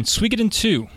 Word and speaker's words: Suikoden: Number 9, Suikoden 2.